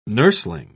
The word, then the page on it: nurseling: British English